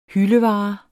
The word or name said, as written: hyldevare